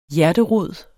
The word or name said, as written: hjerterod